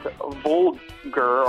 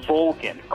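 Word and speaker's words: Volgin: And it's not Volger, or however the fuck you said his name. It's Vulcan, Colonel Vulcan.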